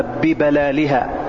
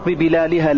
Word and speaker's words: ببلالها: ثم في رواية عند البخاري، طبعا هذا القدر متفق عليه، ثم رواية عند البخاري: غير أن لهم رحما سأبلها ببلالها. وقد تنطق ببلالها لكنها لغة مرجوحة والراجحة، ولكن سأبلها ببلالها،